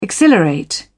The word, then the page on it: exhilarate: British English